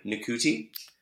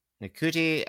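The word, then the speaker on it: Ncuti: Nakuti. – Uh, Nakuti uh, Gatwa.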